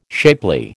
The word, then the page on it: shapely: American English